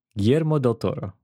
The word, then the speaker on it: Guillermo del Toro: Am I pronouncing it still wrong? – Guillermo? I mean, Guillermo. Guillermo. – Guillermo del Toro. – Yes. There we go.